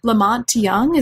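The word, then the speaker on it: La Monte Young: Lamont Young, is that how you pronounce his name? – Yes.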